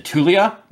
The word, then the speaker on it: Tu'Lia: That'll all get you the, you said Tulia? Is that how I'm pronouncing it today? – Yeah.